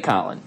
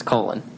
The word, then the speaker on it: Colin: Don't you dare say Colin. It's Colin.